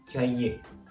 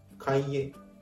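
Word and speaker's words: cahier: これが英語のテキストですから、フランス語で 2 つ合わせると、これがキャイエ・デュ・テクスト。まあ、テキストブック教科書の意味になるわけですね。発音のポイントは単語の前半のキャイエです。カイエとカタカナ読みで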